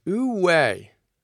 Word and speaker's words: Wu Wei: Our first big idea is Wu Wei, which is spelled W-U-W-E-I.